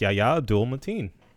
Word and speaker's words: Yahya Abdul-Mateen: Yes, Yahya Abdul-Mateen, the second.